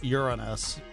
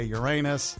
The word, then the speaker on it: Uranus: Everyone gets to bring out their inner fifth grader because the planet is pronounced Uranus. But we all know that most people like to say Uranus.